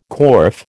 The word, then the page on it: corf: American English